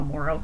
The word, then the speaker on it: Moreau: Oh, Moreau, sorry.